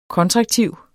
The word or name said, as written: kontraktiv